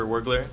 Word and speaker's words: Wirgler: Hi, my name is Christopher Wergler, W-I-R-G-L-E-R. Christopher is spelled the exact same way, C-H-R-I-S-T-O-P-H-E-R.